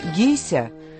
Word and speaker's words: гейша: Слово «гейша» в оригинальном произношении «гейся» состоит из двух иероглифов.